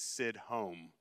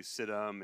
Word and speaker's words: Sidhom: For the record, the correct pronunciation of my last name is Sidhom, but I also answer to Sidhom and Sidhom and anything that sounds anything remotely close to that, I will also answer to.